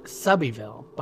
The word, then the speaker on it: Selbyville: Selbyville, which I'm pronouncing as it's spelled, is actually pronounced Selbyville by the locals.